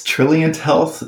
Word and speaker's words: Trilliant: It's Trilliant Health, like spelled like brilliant, but with a T.